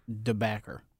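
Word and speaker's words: De Backer: His name is pronounced DeBacker.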